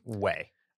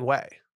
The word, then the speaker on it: OUAI: O-U-A-I is the brand name. – Way. – I've just been saying Way. So it's that's way. – Correct? – It's Way.